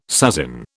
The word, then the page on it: sasin: British English